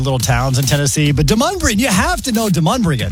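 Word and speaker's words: Demonbreun: Demon Brown.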